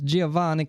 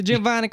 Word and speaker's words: Giovanni: That a scientist, Giovanni Caputo. – Giovanni. You gotta do like, the hand. – Okay, Giovanni Caputo.